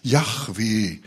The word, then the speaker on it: Yahweh: J-H-W-H, Yahweh. Sagen einige, wird es ausgesprochen. Yahweh, werden die Juden haben so viel Ehrfurcht vor diesem Namen, dass sie ihn gar nicht aussprechen wollen.